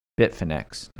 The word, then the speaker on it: Bitfinex: In 2016, somebody stole 119,754 Bitcoins from a Bitcoin exchange called Bitfinex.